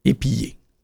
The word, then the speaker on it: épiller: Épillé s'écrit avec deux L et on le prononce en mouillant les L. Épillé.